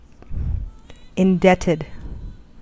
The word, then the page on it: indebted: American English; [ɪnˈdɛ.tɪd]